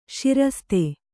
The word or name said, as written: ಸರಸತಿ